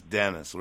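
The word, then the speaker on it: Dannis: which looks like Dennis, although everyone pronounces it Dennis, it seems. – Yeah, it's D-A-N-N-I-S. That's Dennis, right.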